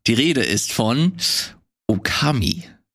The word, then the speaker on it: Okami: Okami. Betonung auf dem O.